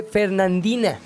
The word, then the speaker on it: Fernandina: La tortuga gigante de Fernindanda. Qué bueno. Que lo dije mal, perdón. Lo dije muy seguro, pero lo dije perfectamente mal. La tortuga gigante de Fernandina.